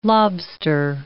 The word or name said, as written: lobster